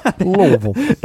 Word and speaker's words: Louisville: You got to say it like you got a mouthful of molasses. Louisville.